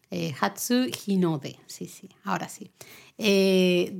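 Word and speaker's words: Hatsuhinode (初日の出): Eh, dentro del Hatsumode está muy relacionado otro primero que es el Hatsunohide. – ¿Lo digo bien? – Siempre lo digo al revés, ¿verdad? – Siempre lo digo al revés. – Me pasa. Hatsuhinode.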